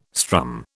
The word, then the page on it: strum: British English